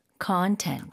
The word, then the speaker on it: content: 콘텐츠가 아니고 콘텐츠. Content. – 아, 앞쪽에 강세가 많이 가네요.